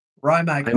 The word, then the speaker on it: Rimac: Rimac. R-I-M-A-C.